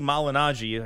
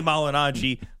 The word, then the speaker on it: Malignaggi: Coming up next, more with Stephen Espinoza, including what really happened with that Paulie Malignaggi. I could say that right for once. Paulie Malignaggi footage.